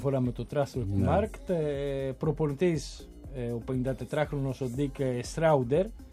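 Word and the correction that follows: Schreuder: αν τον προφέρω σωστά. – Σρόιντερ. – Δηλαδή, τώρα η προφορά των Ολλανδών είναι λίγο περίεργη, δεν έχει καμία σχέση με τα, πώς το λένε, με τα γερμανικά.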